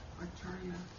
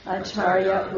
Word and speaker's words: Acharya: What is Acharya mean? Is that the same as I didn't. – Acharya, infertible. – Oh, that means none. And the C's are pronounced with this as a CH.